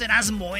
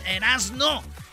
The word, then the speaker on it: Erazno: Bueno, Erasno es E-R-A-Z-N-O. No es, no es Erasmo, eh, es Erasno. Y la Chocolata. Chocolata se escribe con K en la segunda C. Es C-H-O-K-O-L-A.